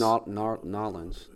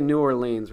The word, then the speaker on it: New Orleans: I just know if we say New Orleans, we're saying it wrong.